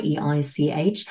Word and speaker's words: Oestreich: You simply type in my first name, Chloe, and my very tricky last name, which is spelled Oestreich.